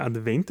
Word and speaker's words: Advent: Im Bundesdeutschen sagt man Advent, also mit einem, das V wie ein W, ja? Mhm. Und im Österreichischen eigentlich mit einem WWF, also Adventsmarkt. Ja. Mhm. Wobei, ich bin eigentlich relativ atypisch, was das betrifft. Ich verwende eher das nicht das F, also die Aussprache als F, ja, sondern als W.